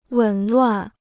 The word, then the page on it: 紊乱: wěn luàn